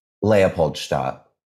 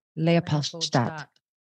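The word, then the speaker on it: Leopoldstadt: Listeners, this is how you say it. – Leopold Stadt. – Leopold Stadt. – Leopold Stadt, yeah.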